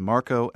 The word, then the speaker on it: Marco: First name is Marco, M-A-R-C-O.